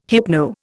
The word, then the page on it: hypno: Indian